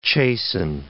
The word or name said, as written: chasten